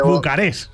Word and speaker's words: Bucarest: Has dicho Bucarest. O sea, es como de, porque, sí señor, ahí ese momento internacional que yo hubiera dicho Bucarest. Con acento en la última, ¿eh? Y sin la T, Bucarest.